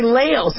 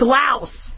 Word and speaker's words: Laos: So, you know, maybe before you guys start getting all mad at me, that I'm, you know, a lot of you guys say Laos. It's called, it's Laos.